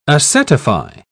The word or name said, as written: acetify